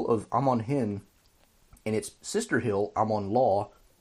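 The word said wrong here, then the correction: Lhaw: over on, well, Lao actually is how it should be pronounced, on the other side of the Anduin.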